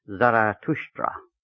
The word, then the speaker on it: Zarathustra: The name Zoroaster in present-day Persian is called Zartosht, and in the ancient Avesta language is pronounced Zarathustra.